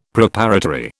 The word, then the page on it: Preparatory: British English